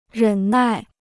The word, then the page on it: 忍耐: rěn nài